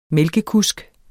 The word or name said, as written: mælkekusk